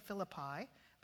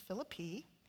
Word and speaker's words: Philippi: And then they journey on to Philippi, which is the correct way of pronouncing it. I know we say Philippi, but it's Philippi.